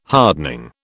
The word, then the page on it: hardening: British English